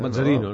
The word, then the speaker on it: Mazzarino: ho pronunciem més a la italiana. – Mazzarino, no?